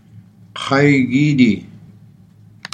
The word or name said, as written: kay giidii